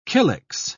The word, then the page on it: kylix: British English